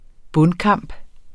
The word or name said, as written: bundkamp